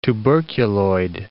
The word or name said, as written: tuberculoid